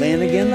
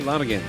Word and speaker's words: Lanegan: You can hear Mark Lan- Lanegan. – Am I pronouncing that right? – Lanegan. Lanegan.